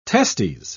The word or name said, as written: testes